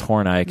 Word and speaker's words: Horney: And the highly respected Karen Horney, or it's spelled Horny. But it's pronounced Horney.